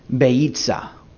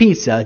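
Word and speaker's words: Beitzah: The fourth item that you find on a Seder plate, I'll spell it for you. B as in boy, E-I-T-Z-A-H. Beitza. Not pizza, Jim, but beitza.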